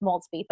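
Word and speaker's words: Maultsby: You can follow her on Instagram at Peggy Maltzby, and it's pronounced, or well, it's pronounced Maltzby, but it's spelled MaultzBy.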